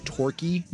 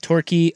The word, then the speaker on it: Turki: So the guy, the Saudi guy, his name is Turkey, not Turkey. It's spelled T-U-R-K-I. – But it's Turkey. – You pronounce it Turkey.